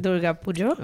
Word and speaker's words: Durga Pujo: How do you say Durga Pujo? Durga Pujo. – Okay, Durga Pujo.